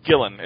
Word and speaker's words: Gillen: Gillen. It's just G-I-L-L-E-N. Gillen. – I'm very conflicted on it. – Yeah, I'm waiting until it plays out to really judge it, because I love Gillen, but this is definitely the weirdest excuse for an Iron Man story I've ever read.